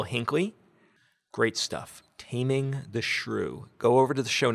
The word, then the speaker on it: SRU: Next up, another podcast, Taming the Shrew. Now, you not might not know how to spell this. It's Taming the S-R-U, but it's pronounced shrew.